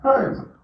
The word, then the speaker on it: herb: So Americans say herb with a silent H.